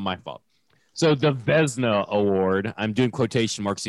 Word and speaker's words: Vezina: Am I even pronouncing that right? – Vezina. – Well, listen. Here's the thing. Don't put an I in there. If it's Vezina, get the I out of there.